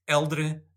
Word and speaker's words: Helder: In Portuguese, it's Eldre Sebastião is how you pronounce the name. But in the anglicized version, if people say Helder Sebastião, I'm happy. And that's what I go by.